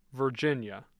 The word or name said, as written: Virginia